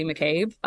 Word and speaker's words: McCabe: The FIFA rep was introducing everyone, so introduced Vera Powell, and then she was like, and the Ireland captain, Katie McCain, and everyone was like, ooh. And Katie straight away was like, it's actually McCabe,